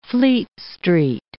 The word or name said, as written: Fleet Street